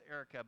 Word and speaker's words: Airwrecka: It's Erica, pronounced Erica, but it's spelled like this. A-I-R-W-R-E-C-K-A.